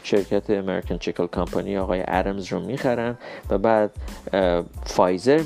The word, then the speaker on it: Adams: ولی بعد نیست که ما بدونیم این آدامز غلطه و ارمز تلفظ صحیحش هست